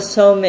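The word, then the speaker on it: Somé: Maladoma Somme is an African man who is wonderful, wonderful writer. His last name is spelled S-O-M-E with a accent on the E.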